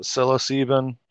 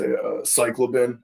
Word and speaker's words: psilocybin: What are your thoughts on, so shrooms or how do you pronounce that? Psilocybin. – Yeah, dude. I always thought it was, the other way to say cyclobin.